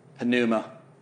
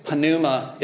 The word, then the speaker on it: pneuma: So here's one thing that I've learned, and maybe, okay, so pneumatology, we would say that this P is silent, right? But in Greek, this is pronounced pneuma.